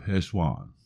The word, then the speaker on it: Cheshvan: Now today, Monday, October 16, 2023, is the first day of Heshvan. Now that is spelled C-H-E-S-H-V-A-N, but the C is silent.